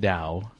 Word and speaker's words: Tao: First of all, you pronounce it Dao.